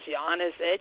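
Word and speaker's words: Janezich: My last name is Janezic, and it's spelled J-A-N-E-Z, like in zebra, I-C-H.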